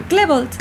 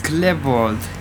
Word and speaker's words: Klebold: ¿Cómo es? A ver. – Klebold. – Clebold. – Sí, ¿no? Ok, sí.